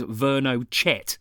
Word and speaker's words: Vernochet: You know how to spell Hugo, but the surname, it looks like it says Vernochet, but I think you pronounce it Vernochet. It is French. V E R N O C H E T.